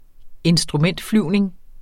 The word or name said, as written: instrumentflyvning